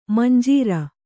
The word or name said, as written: ಮಂಜರಿ